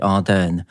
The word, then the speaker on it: Ardern: Ardern. – Częściej ją wymawiają z akcentem na drugą sylabę, czyli Ardern.